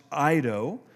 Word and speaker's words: eido: And so here's one of those places where he uses the word know, and it's the Greek word eido, okay, pronounced eido, and it means to perceive with the eyes or senses.